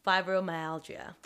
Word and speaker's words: fibromyalgia: He does a study with women with fibromyalgia. Oh my God, I said it right.